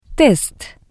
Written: test

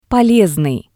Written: polesnii

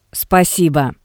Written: Spasibo

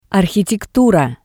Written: Arhitektura

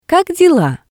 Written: Kak dela

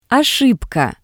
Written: Oshibka

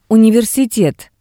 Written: universitet

